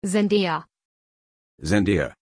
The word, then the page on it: Zendaya: German